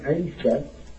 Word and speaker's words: Isa: We Muslims call him Isa because that is the pronunciation before you Latinize it.